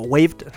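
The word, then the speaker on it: waived: O Waved, mejor, mejor pronunciado, Waved.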